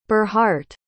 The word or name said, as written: burhardt